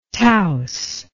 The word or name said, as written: Taos